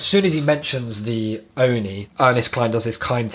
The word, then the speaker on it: Oni: Like, it's not the O-N-I. You say Oni, like Tony.